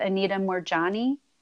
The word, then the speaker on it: Moorjani: Um, I don't know if you're familiar with Anita Moorjani. Yes. I'm, um, Oh, yeah. did I pronounce her name right? Yeah, yeah. yeah she,